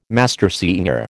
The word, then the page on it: mastersinger: American English